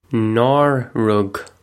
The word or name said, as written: Nár rug